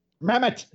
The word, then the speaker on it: Mamet: Mamet.